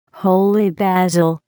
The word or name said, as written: holy basil